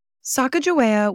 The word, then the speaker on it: Sacajawea: today I'm opting to say her name as some of her contemporary Shoshone family members pronounce it, Sacajawea. Sacajawea was a Lemhi Shoshone woman born around 1788 in Idaho's Lemhi Valley along the Salmon River.